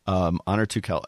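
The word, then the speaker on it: Onur Tukel: It's Honor Tukel, or there's no, it's not Turkel, there's no R. Honor Tukel.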